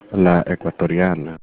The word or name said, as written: ecuatoriana